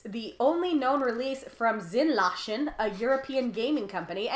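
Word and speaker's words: Sinneslöschen: And yes, I looked up the pronunciation, because based on how it's spelled, I thought it was Sinneslochen. And I thought I was going to be so cool with my German Sinneslochen. And then I was like, Google Translate, how do I say this? And it was like, you dumb bitch, it's Zinlachen.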